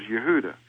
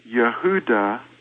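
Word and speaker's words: Yehuda (יהודה): Now, in the word Yehuda, which is the name of the man that's the son of Yaakov, or Jacob's son, Yehuda, they call him Judah. Well, his name wasn't Judah, his name was Yehuda. It's spelled yod, he, ua, daleth, he. The same four letters, with a fifth letter, daleth, inserted just before the last hey.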